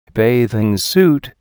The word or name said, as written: bathing suit